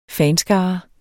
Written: fanskare

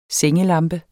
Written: SENGELAMPER

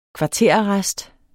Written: kvarterarrest

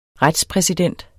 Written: retspræsident

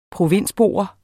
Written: provinsbo

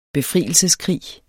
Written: befrielseskrig

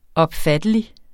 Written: opfattelig

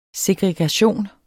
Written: segregation

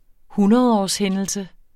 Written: hundredårshændelse

